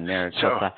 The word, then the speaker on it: Maricopa: But Maricopa County, if I'm saying that right. – Yeah, Maricopa.